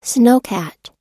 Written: snowcat

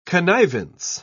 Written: connivance

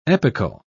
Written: epochal

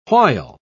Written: while

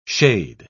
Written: shade

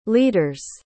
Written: leader's

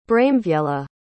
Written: braemwiella

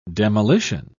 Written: demolition

